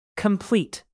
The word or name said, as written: complete